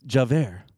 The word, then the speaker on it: Javert: Javert, thank you.